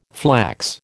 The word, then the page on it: flax: American English